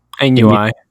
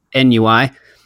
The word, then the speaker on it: ennui: It said, "Through angers, losses, ambition, ignorance," and I don't know how to pronounce this word, ennui? – Ennui. – Ennui.